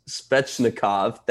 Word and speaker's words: Svechnikov: Svechnikov, thank you.